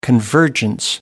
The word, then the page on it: convergence: American English